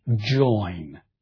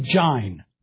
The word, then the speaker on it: join: Pope can rhyme words like L-I-N-E, line, and J-O-I-N, which we would say join. And the reason he can do it is because line, from Middle English, lean, would have probably been pronounced more like line. And join, an old French word that enters English, is probably pronounced join. Line and join. I've been told that there are people in Kentucky who still talk like that, who'll say jine for join.